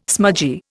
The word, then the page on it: smudgy: Indian